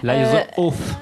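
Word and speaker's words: Liar's Oath: Liars Oath, je dirais. Le TH, le voilà. Fameux TH de merde de ces enfoirés d'anglais.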